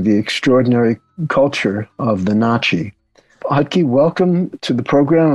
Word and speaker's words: Natchez: Just for our listeners, when I'm saying Na'Chi, that is the traditional pronunciation.